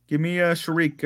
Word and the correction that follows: Shakir: or whatever, how you say his name. – Shakir, yeah.